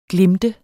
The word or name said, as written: glimte